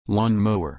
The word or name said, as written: lawnmower